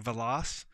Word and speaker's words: Velas: And the script coordinator said, all right, his name, it's like you're saying Velas Vegas.